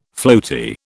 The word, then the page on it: floaty: British English